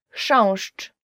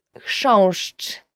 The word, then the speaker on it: chrząszcz: Chrząszcz, beetle. So the first word is, and I promise you this is the most difficult word you will ever learn in Polish, as for the pronunciation, is chrząszcz.